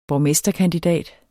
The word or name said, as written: borgmesterkandidat